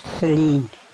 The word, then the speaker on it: Celene: It's Selena or Charlene? – Selene. – Selene, how do you spell that? – C-E-L-E-N-E.